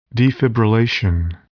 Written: defibrillation

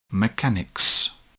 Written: mechanics

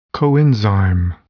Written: coenzyme

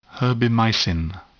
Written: herbimycin

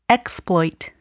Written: exploit

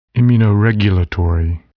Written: immunoregulatory

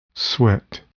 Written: sweat

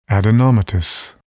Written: adenomatous

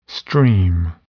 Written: stream

